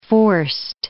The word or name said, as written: forced